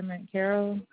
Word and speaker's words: McCarroll: My name is Amber McCarroll.